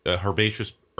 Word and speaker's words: herbaceous: These are, herbaceous. How do we pronounce it here in this country? Herbaceous or herbaceous? – Herbaceous. – Because it's the root being herb.